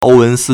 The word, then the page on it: 欧文斯: 歐文斯; Ōuwénsī